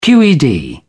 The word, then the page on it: qed: British English